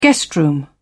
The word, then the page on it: guest room: British English